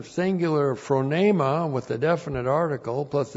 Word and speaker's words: phronema: This is one of Paul's words, nobody else uses this except the Apostle Paul, phronema. P-H-R-O-N, long E-M-A, phronema.